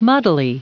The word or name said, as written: muddily